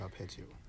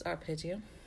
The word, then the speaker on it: arpeggio: I think it's arpeggio. – It's arpeggio. Arpeggio.